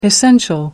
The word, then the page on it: essential: American English